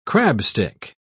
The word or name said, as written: crabstick